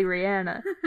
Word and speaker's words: Rihanna: It's technically Rihanna.